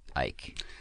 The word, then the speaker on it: Icke: His name is pronounced David Ike. It's spelled I. His last name is spelled I C K E. I want it to be pronounced Icky, but it's Ike.